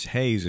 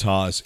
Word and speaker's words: Haas: It's Hayes if you're from around here. – Yeah, it's Haas everywhere else. – It's Haas.